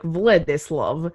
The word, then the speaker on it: Vladislav: I literally looked up how to pronounce it in the correct, like, Romanian, and it was like Vladislav, but I'm not gonna.